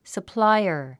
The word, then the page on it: supplier: səpláiə r; サプライア